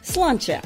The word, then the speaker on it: sláinte: Or you can say sláinte, which is how you say cheers in Irish and literally means health.